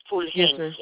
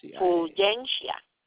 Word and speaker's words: Fulgencia: Fulgencia.